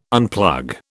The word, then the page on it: unplug: British English